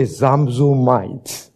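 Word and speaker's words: Zamzummites: Or, I know some of you find it very hard to pronounce. Say Zamzumites. You are not very convinced. – A bit louder. Zamzumite. All right, that's a little bit better.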